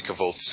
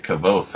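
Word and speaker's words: Kvothe: You guys pronounce it K'voth, huh? Is that the proper way to pronounce that? Okay. – That's how I've heard it. – I've always said K'voth. Hmm. But there is an E at the end of it, isn't there? – Yep.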